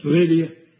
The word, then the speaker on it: Lydia: And it says, and a certain woman named Lydda, Lydia, I don't know how that, really how that's pronounced. – Lydia. – Yeah. Lydia.